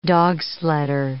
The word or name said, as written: dogsledder